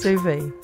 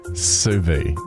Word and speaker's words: sous vide: But I believe it's pronounced boopie boopie boom. Am I right? – No. – Damn. – No. – Sous vide. – Sous vide, yeah. – How do you spell it? – S-O-U-S. – Yeah. – V-I-D-E.